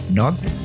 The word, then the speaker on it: Gnod: Nod. Se escribe G-N-O-D, Nod.